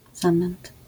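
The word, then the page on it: zamęt: Polish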